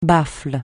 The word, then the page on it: baffle: francais